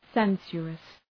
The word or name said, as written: sensuous